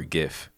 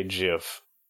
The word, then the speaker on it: GIF: How do you say it, first of all? GIF or GIF? – I say GIF.